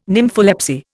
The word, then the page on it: nympholepsy: Indian